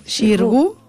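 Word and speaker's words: Xirgu: Eh, como protagonista, como Mariana, tenía a Margarita eh, A ver, a ver si lo digo bien. Shingu. – Shirgu. – Sí.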